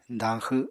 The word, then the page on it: dañxw: apatani